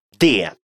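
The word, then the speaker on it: det: Де. Обратите внимание на то, что буковка «т» в этом местоимении не произносится.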